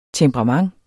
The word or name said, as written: temperament